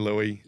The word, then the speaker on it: Louis: And L-O-U-I-S, whenever you see that spelling, you do not pronounce it with an S.